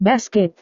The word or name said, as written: basket